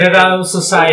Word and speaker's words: Dead Outside Society: Y por ahí hay un podcast que se llama Dead Outside Society. No sé si lo pronuncié bien, mi pinche. Dead Outside Society.